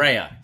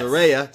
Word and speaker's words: Saraya: Saraya. Say her name right. She makes it very clear. Mm. She wants us all to make sure they're not calling her Saraya. So Saraya is the name.